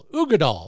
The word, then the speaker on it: Uigeadail: Ardbeg Uggadal. Yeah, that's what it's called. It's spelled Uigeadail. Uggadal.